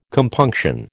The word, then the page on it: compunction: American English